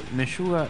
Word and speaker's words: Meshuggah: Se escribe Meyuga, es Meyu Ga con doble G, A y H, ¿viste?